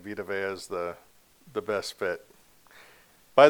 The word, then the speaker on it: Vita Vea: So Vita Vea is the best way to say it.